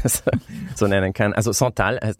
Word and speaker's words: Santal: Und zwar Santal 33. Spricht man das eigentlich so aus? – Santal, ja, Santal 33 ist ein Duft der New Yorker Parfümerie und Kosmetikmarke Le Labo.